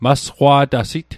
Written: masqwa'tasit

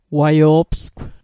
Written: waio'psgw

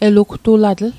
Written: elugtulatl

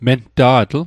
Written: menta'tl

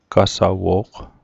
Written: qasawo'g

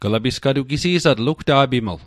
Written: gisi'satl

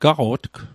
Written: gaqo'tg

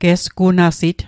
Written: gesgunasit

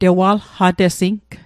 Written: tewalqatesing

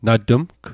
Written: na tmg

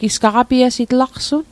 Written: gaqapiasit

